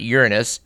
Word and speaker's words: Uranus: That apparently they've been looking out at Uranus, and that is the correct pronunciation we would add for our listeners, from the Keck Observatory, and they now see there's all these bands on it.